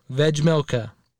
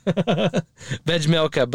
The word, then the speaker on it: Vejmelka: It's Vejmelka. Vejmelka. Veggie and milk. Vejmelka.